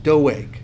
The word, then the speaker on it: Doeg: His name actually looks like Dog. We won't call him Dog the Bounty Hunter, but this guy's name is pronounced Doeg.